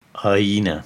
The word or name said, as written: Ajna